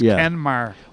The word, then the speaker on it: Kenmare: And, is Kenmare K-E-N-M-A-R? – K-E-N-M-A-R-E. – All right, I've been there. I thought it was Kenmare. – Well, you know, right, but the Irish pronounce it Kenmare, so the emphasis is on the second syllable. – Yeah. – Whereas I would have said Kenmare.